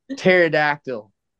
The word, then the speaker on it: pterodactyl: Where did this letter think, like pterodactyl? You know why you can't hear a pterodactyl go to the bathroom? Because the P is silent.